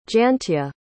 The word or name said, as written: jantje